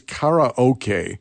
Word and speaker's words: karaoke: Actual pronunciation, by the way, is karaoke, which in Japanese means empty orchestra. Kara, like karate, means empty hand, no weapon. So that's how you actually pronounce it.